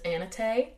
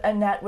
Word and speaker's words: Annette: Now, before we continue, depending on the source you get it from, the spelling of Annette is always the same, but the pronunciation is different. So some people pronounce it Annette. Some places, I think the Norwegian pronunciation is Annette. – Yeah. – Or obviously I'm saying it like a white American, but like, you know, in a Norwegian accent, it would be... – Yeah. Closer to Annette. – Annette. – But Annette would be the more Americanized pronunciation. That's my understanding.